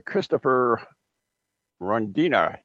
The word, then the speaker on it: Christopher Rondina: He is Mr. Christopher Rondina.